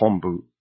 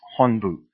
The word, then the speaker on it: Honbu: Most people will pronounce this as Honbu, but it is really pronounced Honbu. And if you can't see the difference there, what I'm referring to is the H-O-N, as in November, or H-O-M, as in Mike.